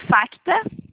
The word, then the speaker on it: FACTA: Ó, é FACTA. – Com F. – Isso. E o C é mudo, é F-A-C-T-A.